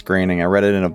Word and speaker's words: Groening: Matt Groening, who made The Simpsons. – Yeah. – I Definitely didn't think that's how you pronounced it, but yeah, okay.